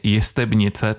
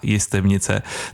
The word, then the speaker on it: Jestebnice: Já jsem se teď během písničky ještě ujistil, jestli mám správnou výslovnost a říkám správně Jestebnice, ta Jestebnice, tedy jednotné, nikoliv množné číslo.